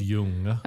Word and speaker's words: Jung: Is it Jung? I never pronounce it that way. I always say Jung. But Jung. – It's Jung. – Okay, Jung. – It's like Swedish. Jung.